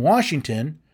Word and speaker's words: Washington: So when I pointed out to this particular teacher that there's no R in Washington, well, he said, well, that's the way I pronounce it.